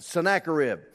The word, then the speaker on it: Sennacherib: And you see that name in the Bible, you wonder how to pronounce it. Well, you just heard. That's how you pronounce it. Sennacherib.